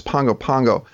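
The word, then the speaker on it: Pago Pago: From there, my route was to, it's actually pronounced Pongo Pongo.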